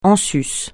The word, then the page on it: en sus: français